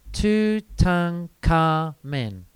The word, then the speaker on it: Tutankhamun: His name is actually pronounced like this, Tutankhamen.